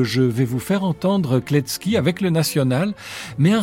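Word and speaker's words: Kletzki: Voici Paul Kletsky, né Pavel Kletsky, ça s'écrit C-K-I, lui remplacera par T-Z-K-I, histoire qu'on le prononce bien.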